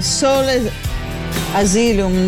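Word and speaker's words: Soul Asylum: Sole Asylum dovrebbe essere.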